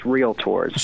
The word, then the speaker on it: realtor: It is probably one of the most mispronounced professions out there. It is realtors. – So there's not the law in the middle. Realtors. – Yeah, okay. – There is no realtor, but that's the most common way that we hear people pronounce it, but it's realtor.